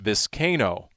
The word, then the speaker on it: Vizcaino: Like some sort of middle infielder in baseball, or as your head coach called you in the post-game locker room, it's Vizcaino, apparently, is the correct pronunciation. – K-A-Y, that you pronounce the K in there and don't add an extra syllable.